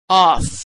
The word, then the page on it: off: female